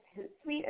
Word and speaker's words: Hootsuite: Um, we just recently learned a little bit about, I think it's Hootsuite.